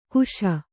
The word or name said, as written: ಕಶಿ